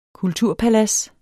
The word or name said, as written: kulturpalads